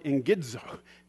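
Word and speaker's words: engidzo (ἐγγίζω): engidzo. Engidzo. If I can say it right, the ing and the id is separate.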